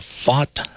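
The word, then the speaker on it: Pfaadt: Landon LaRoupe against Brandon Flott. I'm mispronouncing that. Brandon, I looked that up. Fought, I believe. Yeah, it's kind of like fault. Brandon fought.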